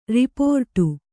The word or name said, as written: ರಿಪೋರ್ಟು